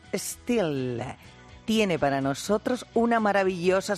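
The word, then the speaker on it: Stihl: Ay, qué bien lo has pronunciado. – ¿Verdad? – A ver, repite. – Still. – Ole. – Still. ¿Cómo es? Sin la E, porque es una S, ¿cómo se escribe? S-T-H-I-L.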